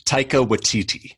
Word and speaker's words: Taika Waititi: How do you say the director's name? I always bet you. – Taika Waititi. – Thank you.